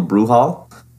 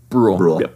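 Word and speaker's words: Brühl: So Rush is directed by Ron Howard, starring Chris Hemsworth and Daniel Bruhl. Did I pronounce that wrong? – Bruhl. – Yeah.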